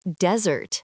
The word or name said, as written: desert